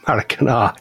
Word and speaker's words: Maracanã: And the other one is Rio de Janeiro's Maracanã. – Maracanã, I pronounced that right. – Maracanã.